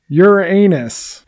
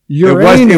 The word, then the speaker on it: Uranus: Or Uranus. I like when they tried to get everybody to pronounce it differently. Uranus. – But Uranus has always been there, right? – Carl Sagan called it, out in the cosmos, Uranus. Mm-hmm. It's like, no, it's Uranus.